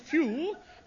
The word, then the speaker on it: Pugh: N-E-D-D-I-E. Neddy. What was next? – Neddy Pugh Seagull. – Pugh? P-H-E-W? – No. It's pronounced few, but it's spelt pug. – Pug, yes. P-U-G-H.